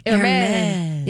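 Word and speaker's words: Hermès: Can you spell it, please? – H-E-R-M-E-S? – Hermes. – Hermé. – Yeah, an Hermé. Like Hermé.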